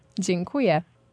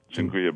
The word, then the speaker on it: Dziękuję: Dziękuję. – Dziękuję bardzo.